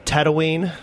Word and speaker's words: Tatooine: It's actually called Tatooine. – Oh. – It's a quick double consonant.